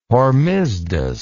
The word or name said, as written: hormisdas